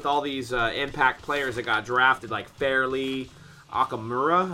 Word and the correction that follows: Amukamara: am I saying his name? Probably not saying his name right. Robert Quinn from the worst team in the NFC West. – Fighting St. Louis Rams. – I'm really stuck on getting this guy's name right. Amukamara.